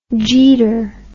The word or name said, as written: jeeter